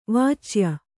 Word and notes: ವಾಚ್ಯ: Kannada

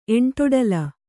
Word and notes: ಎಂಟೊಡಲ: Kannada

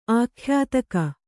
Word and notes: ಆಖ್ಯಾತಕ: Kannada

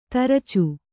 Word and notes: ತುರಚೆ: turace

